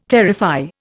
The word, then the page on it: terrify: Indian